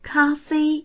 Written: ka1fei1

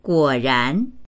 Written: guo3ran2

